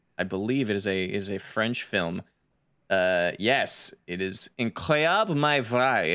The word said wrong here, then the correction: Incroyable mais vrai: Incroyable mais vrai would be incredible but true is the title.